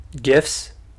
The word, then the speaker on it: GIF: Like, people say GIFs. I say GIFs. – Oh, because yeah. I read the creator's comments on that, uh-huh. and he's like, it's pronounced like the peanut butter.